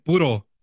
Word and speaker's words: puro: I got somebody named Reynaldo Aguinaga saying pure Boyle Heights, saludos. – No, say it right, bro. Hey, say it right. He said puro Boyle Heights. – Puro.